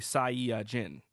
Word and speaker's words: Saiyajin: So, in turn, you get Saiyajin, which I believe is, like, five syllables. It's actually Saiyajin.